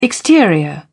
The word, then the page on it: exterior: British English